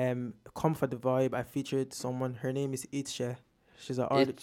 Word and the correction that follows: Ishe: It's actually pronounced. It's called Ishe. It's Nigerian.